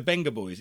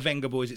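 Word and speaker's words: Vengaboys: It's the Benga Boys. It's pronounced. It's not the Venga Boys.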